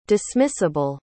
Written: dismissable

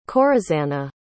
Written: corazana

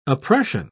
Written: oppression

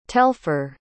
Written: telfour

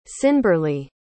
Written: cynburleigh

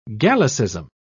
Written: gallicism

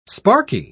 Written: sparky

